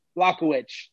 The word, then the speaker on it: Błachowicz: They call him the Polish power, Jan Błachowicz. But, you know, Israel's looked very good. He's looked unbeatable. Błachowicz.